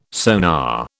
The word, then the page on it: sonar: British English